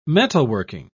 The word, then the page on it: metalworking: British English